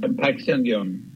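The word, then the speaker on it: Patxi Andión: Paxi Andion.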